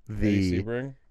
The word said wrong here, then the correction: Sibrian: Is that how you say his name? – Eddie Sibrian. – Is it Sibrian? – Yeah.